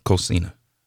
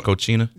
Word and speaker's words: Cocina: Soulful Cocina. – I'm so glad to hear you say it because I done mispronounced it so many times. – Saying Cochina? – Yeah, I done said, I done called it just about everything except what it really is. So that's why I wanted you to pronounce it so that I wouldn't mess it up trying to tell everybody else. – Yeah, so it's a Soulful Kitchen. Cocina's Kitchen.